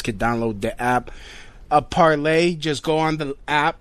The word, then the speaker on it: Parler: That's P-A-R-L-E-R. That's P-A-R-L. I mean P-A-R-L-E-R. So it's parlor, but it's pronounced parlay, as in like a free speech. Haha, free speech in French.